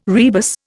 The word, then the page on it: rebus: Indian